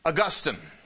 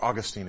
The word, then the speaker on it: Augustine: Augustine, or Augustine if you want to pronounce it that way, was a wicked, wicked man.